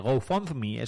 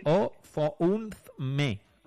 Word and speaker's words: GoFundMe: nada, si lo has dicho estupendamente, GoFundMe, es como sí. ¿Cómo se dice? GoFundMe. Así, tal cual.